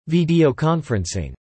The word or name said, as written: Videoconferencing